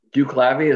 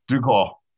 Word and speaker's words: DuClaw: Duclavy, is that the way you pronounce it? – Duclaw. – Duclaw. Okay. I couldn't tell where the things ended. Yeah. – No, it's Duclaw. And there was, that's a weird one, but none of them work there anymore.